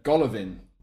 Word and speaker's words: Golovin: In particular, I'd like to point out Alexander Galov. I'm going to try and pronounce his name correctly. Alexander Golovin.